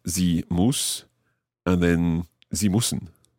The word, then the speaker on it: müssen: Sie müssen. – But ich muss. – Ich muss. – So there's a difference in the vowel sound there. Sie müssen, but ich muss. – Ü. And U.